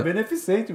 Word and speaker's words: beneficente: Beneficiente. Beneficiente. É. Beneficiente. Falei errado ainda. Não, é beneficente mesmo.